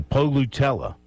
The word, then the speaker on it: Polutela: And then I learned that in Hawaiian, all the vowels are pronounced. So in his last name, you've got an O, you've got a U, you've got an E, you've got an A. So Polutela, Songa Polutela is the last name.